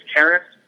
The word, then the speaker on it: Caris: The guy I was really impressed with more than anybody else, even Russell, was Caris LeVert. I think I'm saying that right, It's Caris, Colin? – Yep.